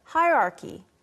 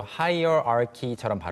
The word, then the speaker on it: hierarchy: 네, 빠르게 발음하면 정말로 hierarchy처럼 발음이 되기보다는 마치 대충 발음하는 사람들은 hierarchy. – Hierarchy. – 네. – I know, I do that. – 네. – Hierarchy. – 이렇게 발음이 많이 됩니다.